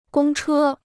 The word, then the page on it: 公车: gōng chē